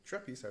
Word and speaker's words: Trappiste: Trappiste, I would say.